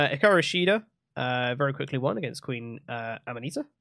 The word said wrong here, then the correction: Aminata: Is that is that how you say it? I think it's Aminata.